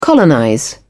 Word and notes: colonize: British English

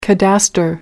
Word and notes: cadastre: American English

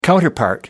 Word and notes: counterpart: American English

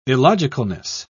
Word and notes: illogicalness: British English

